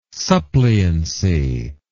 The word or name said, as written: suppliancy